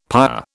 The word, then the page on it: pyre: British English